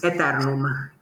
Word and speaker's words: Aeterum: Okay, it's Aeterum. It's Latin.